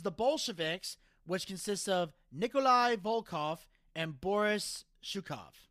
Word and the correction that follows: Zhukov: All right, I got to stop you there. Zhukov. – Thank you. – No, seriously, Zhukov. That's how you say it.